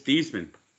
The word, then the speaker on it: Theismann: When he went to Notre Dame, they suggested he change his name to change the pronunciation of his name to Theismann because it rhymed with Heisman.